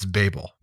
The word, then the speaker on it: Babel: And yes, he said that correct. It's Babel.